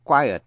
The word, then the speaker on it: quiet: Và động từ quit, Q-U-I-T. Trước hết chữ quiet có thể là tĩnh từ, có thể là danh từ và có thể là động từ. Nhưng nhớ cái quiet có hai syllables.